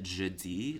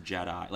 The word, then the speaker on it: Jedi: I pronounced it jeudi, like, in the French way. And amazing. – And everyone, and, like, a very good-humored way, they just, like, they were like, oh my god, Bowen thinks it's pronounced jeudi, it's Jedi. Like, they were like, it's an American pronunciation, it's English, it's Jedi.